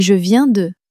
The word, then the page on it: je viens de: french